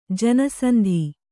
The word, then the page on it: ಜನಸಂಧಿ: kannada